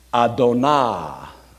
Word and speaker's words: Adonai: And here, the Hebrew would sound something like this. I say that because I'm not that great at pronouncing Hebrew, but I did study it for 2 years in seminary. Anah, Adonah, Ha'el, the God, Ha'el, Ha-Gadol, which is great, Gadol, and Ha-Gadol means the great.